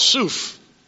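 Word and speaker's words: soof (סוף): They're called soof. S-O-O-F is the way you would spell it in our alphabet.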